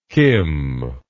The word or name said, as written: kim